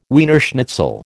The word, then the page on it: Wiener schnitzel: American English